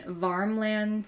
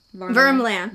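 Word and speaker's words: Värmland: They were born on November 3rd, 1967, in Varmland, Sweden. I hope I'm saying that right. Varmland. – Vermland. Vermland. I wrote down the pronunciation.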